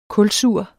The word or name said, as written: kulsur